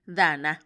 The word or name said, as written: dana